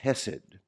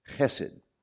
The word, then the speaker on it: hesed: This, in the Hebrew language, is the word hesed. It has that guttural pronunciation that's common in the Hebrew language, hesed. Can you say that? You got to kind of get it down here, hesed. You got a little vibration right there, hesed.